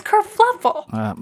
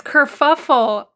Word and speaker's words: kerfuffle: Really? Is it kerfuffle? No, it's kerfluffle. – But we have an episode title. – Oh, no. Is it kerfuffle? Oh my god, it's kerfuffle. I've said it wrong my whole life. I thought it was kerfluffle.